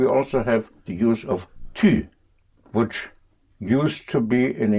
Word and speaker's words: tu: The how to use it is very simple, because tu, T-U, pronounced tu, again, you have the U.